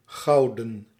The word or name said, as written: gold